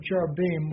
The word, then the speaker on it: cherubim: But he couldn't break that sin because God put the cherubim, or cherubim would be the proper pronunciation from the Hebrew.